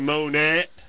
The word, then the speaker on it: Monette: T-T-E, Monette. Monet would be without the T-E. – Without the extra T and the E. What's happening there, Monette?